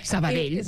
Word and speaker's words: Sabadell: Que no sé pronunciarlo bien, ya lo sé. – Sabadell, pues dit molt bé.